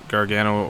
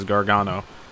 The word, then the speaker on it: Gargano: Johnny Gargano, or sometimes pronounced as Gargano, is Johnny Wrestling.